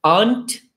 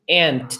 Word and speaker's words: aunt: Você pode tanto dizer aunt como aunt, também que é a mesma pronúncia de formiga.